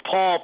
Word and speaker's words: Paul: It's Paul, P-A-U-L, and my last name is spelled G as in George, M as in Mary, I, T-T, that's Tom, E-R.